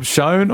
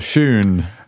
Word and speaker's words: shewn: and it was spelled S-H-E-W-N. – So shown or shoon? – But, yeah, in the 20th century, it was tended to be pronounced show rather than shoe. But if we go back far enough, it is shoe.